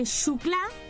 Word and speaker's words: Xuclà: Y si no tiene correo electrónico, nos escribe a la siguiente dirección poniendo programa Radio Benjamín, calle Xuclà, se escribe con X, calle Xuclà.